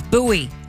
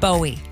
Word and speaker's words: Bowie: And if you're local, you know how to say it. It's Bowie, not Bowie.